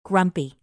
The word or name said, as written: grumpy